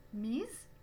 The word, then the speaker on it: mise: Mise on prononce the, mise, et le participe passé féminin du verbe mettre. Au masculin, on prononce mi.